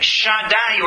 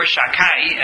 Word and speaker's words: Shaddai: V'im Shaddai or Shakai, as we usually pronounce it, Shin Dalet Yud is a name of God.